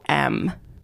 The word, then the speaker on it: Metacom: It's Metacom.